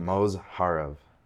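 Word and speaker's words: Mozharov: Mosarov. Something like Mosharov. – Yeah. – That's how you say it.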